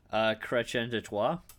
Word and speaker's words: Chrétien de Troyes: Chrétien de Troyes? – That's his name.